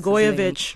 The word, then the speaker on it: Blagojevich: Reading the affidavit, it was kind of funny when Governor Blago, I'm not even going to try to pronounce Blagojevich. – His name. – Blagojevich, yeah.